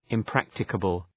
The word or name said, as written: impracticable